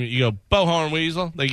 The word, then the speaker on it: Bowhorn Weasel: B-O-W. Second name, Horn. H-O-R-N. – Oh, there it is. Bowhorn Weasel.